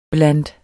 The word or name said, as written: blandt